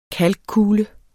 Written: kalkkule

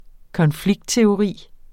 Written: konfliktteori

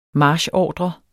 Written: marchordre

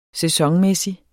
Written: sæsonmæssig

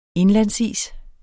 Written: indlandsis

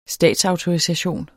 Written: statsautorisation

